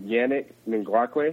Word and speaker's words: Yannick Ngakoue: Yeah, I like watching Yannick Ngakoue.